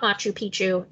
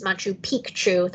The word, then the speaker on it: Machu Picchu: It was visit to Machu Picchu, which I also learned on this trip is not Machu Picchu. It's Machu Picchu. That first C is pronounced.